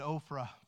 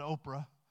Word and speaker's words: Ophrah: Verse 11, it says, the angel of the Lord came down and said unto the oak at Ophrah, not Oprah, they belong to Joash the Abizrite.